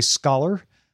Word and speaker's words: Scaller: The main character is January Scholar. Get it? Scholar, even though it's spelled S-C-A-L-L-E-R. It's pronounced like she's a scholar.